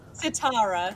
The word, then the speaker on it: Sitara: Sitara. – S-I-T-A-R-A. – S-I-T-A-R-A? – I googled it after you said it.